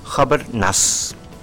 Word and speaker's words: Hubert Nuss: Pero vamos a pasar a esto ahora, muy lindo, un pianista alemán, Hubert Nuss, viste cómo lo pronuncié, ¿no? Esta también la googleé, a ver cómo se pronuncia en alemán, Hubert Nuss.